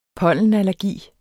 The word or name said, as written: pollenallergi